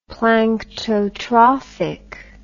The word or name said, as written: planktotrophic